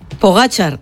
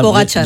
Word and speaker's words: Pogacar: Un amigo nos dice también por WhatsApp que pronunciéis por favor bien el nombre de Pogacar. – Pogacar, ya. Es verdad, Pogacar.